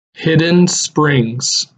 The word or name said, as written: Hidden Springs